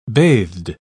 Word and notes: bathed: British English

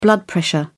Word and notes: blood pressure: British English

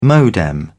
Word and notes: modem: British English

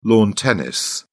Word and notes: lawn tennis: British English

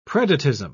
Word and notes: predatism: British English

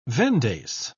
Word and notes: vendace: British English

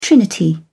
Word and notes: trinity: British English

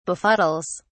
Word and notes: befuddles: British English

